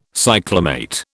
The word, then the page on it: cyclamate: British English